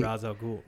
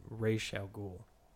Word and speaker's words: Ra's al Ghul: In Nolan's Batman, they pronounce it Ra's al Ghul, but I believe in the Arrow show they pronounce it Ra's al Ghul.